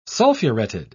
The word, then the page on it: sulfuretted: British English